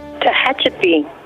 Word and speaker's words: Tehachapi: Tehachapi.